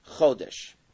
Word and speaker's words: chodesh: In Hebrew, the word year means shana. Now, how do you say Hebrew, the year? Shana. How do you say month? Chodesh. What's the difference between chodesh and shana?